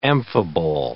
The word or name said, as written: amphibole